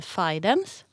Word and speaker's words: Fidens: Heter det Fiddens eller Fidens? Och då sa han att det heter Fidens.